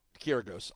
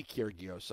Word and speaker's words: Kyrgios: And that's not how you pronounce his name. – Kyrgios, I know, whatever. Yeah. It should be Kyrgios, okay? So I have to say Kyrgios. There's no sound between the R and the G.